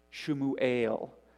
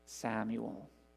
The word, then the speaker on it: Shmuel: Shmuel is what she named him, which we pronounce Samuel.